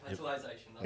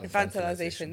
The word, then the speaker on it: infantilization: What's the word? Infantilation? – Infantilation. – Has it? – Infantilization. – Infantilization. There we go.